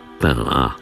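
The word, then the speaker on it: Pinhas: gitarzysta Richard Pinas, założyciel grupy Eldon. Nazwisko gościa kapitału to biblijne imię, które na ogół wymawia się fonetycznie, natomiast po francusku Richard to Richard Pinas.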